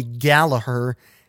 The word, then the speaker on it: Gallagher: It might very well be Gallagher, if that is the Irish pronunciation, if that's what she uses. Again, that's G-A-L-L-A-G-H-E-R.